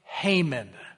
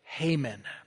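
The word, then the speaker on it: Heman: Now, I'm deliberately pronouncing it that way, because in my generation, there was one called He-Man. And he had this blonde hair with a bowl cut with bulging muscles. And maybe some of you have had an action figure. And I'm afraid if I kept using the word He-Man, that it would throw you off. So I'm going to use the pronounce it this way, Haman.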